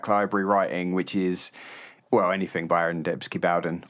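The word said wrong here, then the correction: Dembski-Bowden: Yes Aaron Dembski Bowden, as I heard someone call him today. So I think I've been saying his surname wrong.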